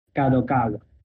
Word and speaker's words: Kadokawa: así como la nueva oficina de la editorial Kadokawa.